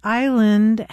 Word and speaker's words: island: Why does island have an S in it, and why is it pronounced with a long I?